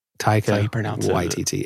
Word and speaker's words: Taika Waititi: I had somebody say, why are you pronouncing it like that? Taika Waititi. It's because it's how he pronounces it. That's correct.